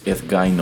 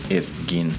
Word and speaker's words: Ed Gein: Ed Gain o Ed Gein. Allí depende cómo lo quieras pronunciar según, eh, según lo leas o lo veas o lo escuches.